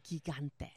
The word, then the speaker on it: Gigante: Gigantes. – Gigante, Robin. Gigante. The G is silent. – Yeah, it's pronounced as an H.